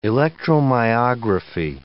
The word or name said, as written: electromyography